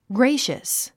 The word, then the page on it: gracious: ɡréiʃəs; グレイシャス